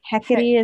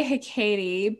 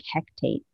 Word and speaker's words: Hecate: I've even heard Hecate pronounced like Hectate, which is weird because there's no extra T in there, and I'm just like, why?